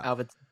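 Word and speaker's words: Avildsen: Alvildsen. I think that's, is that how you say it? Alvildsen. Alvildsen. – You said it right. – Yeah. – Alvildsen.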